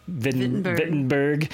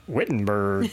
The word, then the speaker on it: Wittenberg: And he was talking mostly about on the podcast today, the Wittenberg, or as he was pronouncing it, Wittenberg, the German pronunciation. So I guess I got to start calling it Wittenberg now, since he calls it that, not the Southern English Wittenberg.